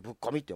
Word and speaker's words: 特攻: 特攻って書いてぶっこみって読むんですけども、